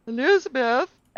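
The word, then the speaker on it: Elisabeth: And they're like, Elizabeth, and oh, is this supposed to be pronounced differently because it's an S? No, it's spelled the same. It's just a letter difference.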